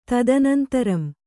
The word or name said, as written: ತದನಂತರಂ